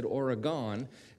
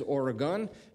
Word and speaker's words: Oregon: We had a group on the show from Oregon, and I said Oregon, and I must have gotten 24 emails correcting me, which I know pronouncing hometown places is important. So it is Oregon,